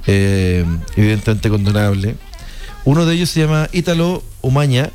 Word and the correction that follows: Omegna: Omeña, se escribe Omegna, pero la G con la N e Ñ, Omeña, que es uno de los asesores además del Partido Nacional Libertario, ¿ya?